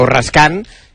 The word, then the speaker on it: Raskin: Yo lo veo complicado, pero tiene alguna posibilidad porque el otro día ante Estados Unidos el doble pivote fue Onana y Raskin, o Raskan, que es como se pronuncia, y le salió bien.